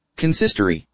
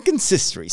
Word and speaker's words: consistory: Consistory. – There you go. I said it right. I knew it. Consistory.